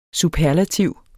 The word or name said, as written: superlativ